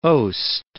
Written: oast

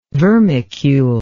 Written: vermicule

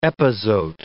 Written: epazote

